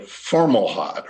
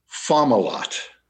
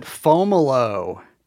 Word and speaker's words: Fomalhaut: But the pronunciation that I use is Fomalhaut. – Like Camelot sort of rhymes? – Kind of like Camelot. – Yeah. – But Fom. Like F-O-M with the accent there, Fomalhaut.